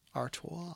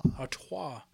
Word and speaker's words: Artois: Artois. – Artois.